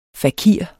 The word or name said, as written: fakir